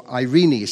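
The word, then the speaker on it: eirene: The Old Testament word for peace is shalom, and the New Testament word is irene, or Irene. Some people pronounce it Irene, from which we get the name Irene.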